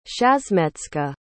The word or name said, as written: shasmecka